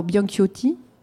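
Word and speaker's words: Bianciotti: celui de l'Argentin Hector Bianchiotti. Je ne sais pas si je prononce bien. – Et tout à fait.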